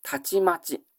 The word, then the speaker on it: 忽: B の 11 番、忽然として姿を消すなんていうときに、たちまち、突然、思いがけずなんていうことですが、この忽然の忽の字だけを取りますと、簡単の C の 13 番、たちまち。というふうにも読めます。忽然という読み方とたちまちという読み方、一緒に覚えてください。